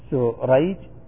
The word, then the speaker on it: سريج: قال: أو بالعكس، كأن تختلف الأسماء نطقا وتأتلف خطا وتتفق الأباء خطا ونطقا، كشريح بن النعمان وسريج ابن النعمان. قال: الأول بالشين المعجمة والحاء المهملة وهو تابعي يروي عن علي، والثاني بالسين المهملة والجيم وهو من شيوخ البخاري.